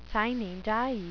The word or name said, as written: sine die